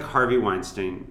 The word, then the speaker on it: Weinstein: So I don't think it's like Harvey Weinstein where it...